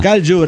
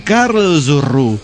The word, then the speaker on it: Karlsruhe: Que Galjur se pronuncia Karlsruhe. Karlsruhe.